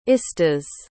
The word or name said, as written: istas